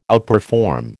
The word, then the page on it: outperform: American English